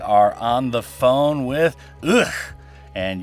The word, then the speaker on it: UGHH: And we are on the phone with UGHH, which is the canonical pronunciation, ladies and gentlemen.